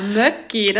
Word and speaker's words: Mökki: Mökki? Habe ich das richtig ausgesprochen? – Ja, Möcki, das hast du richtig ausgesprochen, genau.